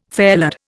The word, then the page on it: fairlead: Indian